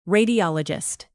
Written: radiologist

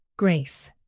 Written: grace

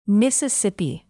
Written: mississippi

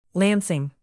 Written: lansing

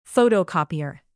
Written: photocopier

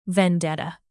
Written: vendetta

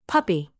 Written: puppy

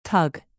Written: tug

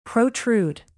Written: protrude

